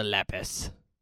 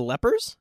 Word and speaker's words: Lepus: We're watching Night of the Lepus. – I've never even heard... Night of the Lepers? – Lepus. – Lepus.